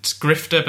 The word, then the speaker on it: GRFTR: The website is Grifter, but it's spelt out like a tech brand, GRFTR.news, Grifter.news.